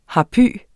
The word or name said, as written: harpy